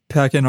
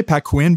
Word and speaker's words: Paquin: Um, I'm saying that right, Paquin. Pack-in artist agency, yeah. Some people say Pack-Quinn, but I am going to go with Pack-in because I think that sounds better.